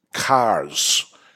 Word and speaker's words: CARS: How about CARS? And this is a New England word that we pronounce wrong. And CARS. – We don't, CARS, right? But C-A-R-S, pronouncing the R, Cigar Authority Review Society.